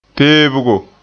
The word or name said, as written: peebgo